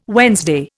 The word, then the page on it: Wednesday: Indian